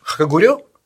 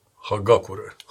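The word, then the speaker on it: Хагакурэ: Наша с тобой любимая, как ты произносишь, Хагурё? Хакагурё? – Хагакура. – Хагакура? – Да.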